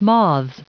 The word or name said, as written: moths